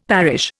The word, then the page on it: parish: Indian